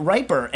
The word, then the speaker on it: Riper: And it's because, it's spelled R-I-P-E-R, so there's a question. And as everyone knows, I'm horrible at pronouncing names. So he hopefully added in parentheses, pronounced like the adjective, a riper apple beats an unripe one.